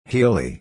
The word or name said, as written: hilly